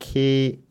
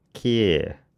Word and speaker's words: Keir: I just looked up Keir. I think it's pronounced Keir. Keir.